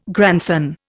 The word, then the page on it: grandson: Indian